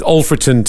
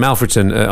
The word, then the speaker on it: Alfreton: And we'll start with Alfreton Town. We were calling them Alfreton on commentary until they said, actually, Alfreton is how you pronounce it.